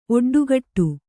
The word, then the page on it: ಒಡ್ಡುಗಟ್ಟು: oḍḍugaṭṭu